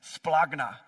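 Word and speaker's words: splagna (σπλάγχνα): But the Greek word is, the way we would pronounce it, is splagna.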